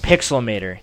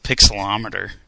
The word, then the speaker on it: Pixelmator: I've always pronounced it Pixelometer. – Well, you added a letter to it. But it is Pixelmator.